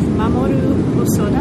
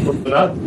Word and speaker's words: Mamoru Hosoda: And this is the figure of Mamoru Hosoda. Am I pronouncing that sort of not entirely wrong? – That's just right. Okay. Mamoru Hosoda. Just perfect.